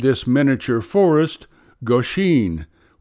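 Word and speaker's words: Goshin: like the famous living artifact known as Goshen, pronounced Goshen.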